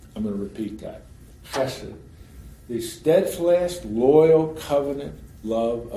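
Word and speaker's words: chesed (חסד): the Hebrew word is chesed. It's guttural, chesed. Don't pronounce it out loud, but to yourself, chesed.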